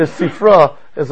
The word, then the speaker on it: Sifri: Again, the Midrash Halacha, he calls Sifri, even though if you're more specific, the Mechilta is on Shmois, the Sifra is on Vayikra, and the Bamidbar Devarim is called Sifri. Sifrai, some people pronounce it.